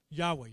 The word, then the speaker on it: Yahweh: But here, the word, the Hebrew word is YHWH, I do not know how to pronounce it. That's an English version. Yahweh, you're correct.